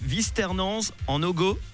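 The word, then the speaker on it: Vuisternens-en-Ogoz: Eh ben super, je l'ai bien prononcé, Visternance en Ogo.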